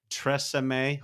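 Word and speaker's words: TRESemmé: tresemme hope i pronounced that right saint i did okay yes.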